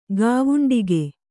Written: ಗಾವುಂಡಿಕೆ

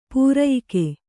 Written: ಪ್ರಯೋಗ

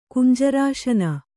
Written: ಕುಂಜರಾಸನ

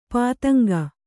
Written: ಪತಂಗ

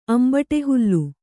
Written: ಅಂಬಟೆಹುಲ್ಲು

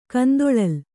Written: ಕಂದೊಳಲಿ

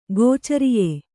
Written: ಗೋಚರಿಯೆ

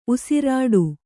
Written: ಉಸಿರಿಡು

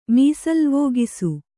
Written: ಮೀಸಲ್ವೋಗಿಸು

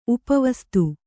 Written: ಉಪಾವಸ್ತೆ

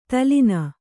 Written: ದಲನ

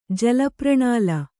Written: ಜಲಪ್ರಣಾಲ

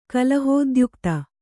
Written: ಕಲಹೋದ್ಯುಕ್ತೆ

